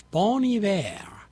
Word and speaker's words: Bon Iver: Well, I just found out that I am pronouncing it wrong. It's Bon Iver.